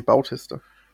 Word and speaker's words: Bautista: Und ich, und ich mag einfach Dave Batista. – Äh, wird er so Batista? Mhm. Wie würdest du ihn dann aussprechen? Bautista? Bautista. Nee, Bautista. Beautisti.